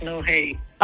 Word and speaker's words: NOH8: Am I saying that right? Is it NO or N0? – It's no hate.